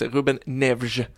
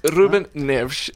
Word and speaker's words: Ruben Neves: Hur är det nu? Ruben Neves? – Absolut inte. Jag vill spy när jag hör det uttalet. – Ruben Neves.